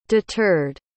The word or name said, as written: deterred